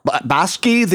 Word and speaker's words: Bakshi: Well, it's a tribute to Ralph Bosch. I'm not pronouncing his name. Bakshi, the Ren and Stimpy guy.